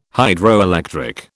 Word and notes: Hydroelectric: British English

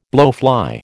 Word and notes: blowfly: American English

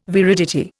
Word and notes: viridity: Indian

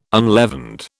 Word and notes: unleavened: British English

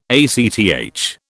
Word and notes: ACTH: British English

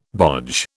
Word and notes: bodge: British English

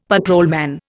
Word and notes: patrolman: Indian

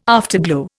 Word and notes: afterglow: Indian

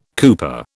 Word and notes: cooper: British English

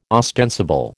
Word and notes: ostensible: American English

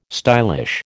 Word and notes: stylish: American English

